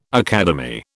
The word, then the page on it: academy: British English